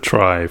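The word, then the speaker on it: trie: We can dig into a little bit more about this key value structure later, but a prefix tree is usually called a tri, or I pronounce it tree still because it's T-R-I-E, and that name comes from that it's a retrieval tree.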